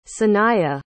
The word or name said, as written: saniyah